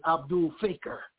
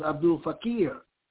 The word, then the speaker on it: Abdul Fakir: In our ignorance, we used to call, because we didn't know anything about al-Islam, we didn't know how to pronounce Arabic, we called him Abdul Faker. We thought that was funny. He's a faker, Abdul Faker. His name was Abdul Fakir.